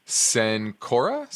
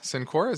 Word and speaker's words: Cencora: He wants to look at COR, which is Cencora. Cencora, is that how you pronounce it? – Yeah, okay.